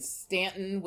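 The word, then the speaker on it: Staunton: I live in the Shenandoah Valley in Stanton, which is spelled Stauntown, but pronounced Stanton.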